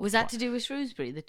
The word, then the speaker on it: Shrewsbury: But there's half of the crowd in Shrewsbury get really angry when you say Shrewsbury because half of them pronounce it Shrewsbury.